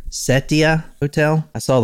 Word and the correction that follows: Setai: Setai. The Setai Hotel.